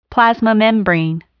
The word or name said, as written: plasma membrane